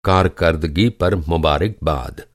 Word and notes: کارکردگی: कारकरदगी

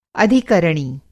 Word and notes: अधिकरणी: хинди

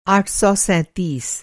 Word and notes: आठ सौ सैंतीस: آٹھ سو سینتیس

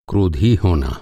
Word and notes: angry: American English

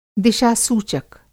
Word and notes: दिशा-सूचक: Hindi

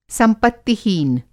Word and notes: संपत्तिहीन: Hindi

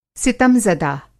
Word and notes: सितमज़दा: Hindi

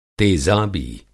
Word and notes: तेज़ाबी: تیزابی; Hindi

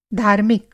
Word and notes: religious: American English

